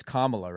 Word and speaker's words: Kamala: I think, I know you don't pronounce her name Kamala. It's Kamala, right? – Yeah.